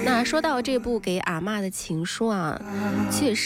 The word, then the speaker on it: 阿嬷: 广东技术师范大学的教授林伦建议大家提到这部电影的时候要读的是四声阿嬷，指的就是奶奶。